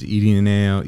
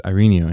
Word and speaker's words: Irineo: And I would say how they call me in school, which is Irineo. And he'd say, no, your name's Irineo.